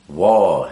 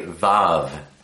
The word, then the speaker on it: Waw: A lot of people like to say Vav instead of Waw, but the pronunciation of the V or the letter V came much later in time. Originally, it's the or more ancient is the W.